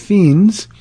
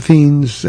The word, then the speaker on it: Fiennes: She says, talking about movies, would you recommend the 2003 movie Luther with Joseph Fiennes? I think that's how you pronounce it. It's F-I-E-N-N-E-S.